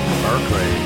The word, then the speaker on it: Berkeley: They took the land, kicked out the Spanish, and they named the place Berkeley, after Bishop Berkeley, except they pronounced it Barclay.